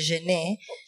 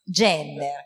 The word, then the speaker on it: Jenner: che credo sia di Genet, si legge Genet, Jenner, oh, quindi non è francese,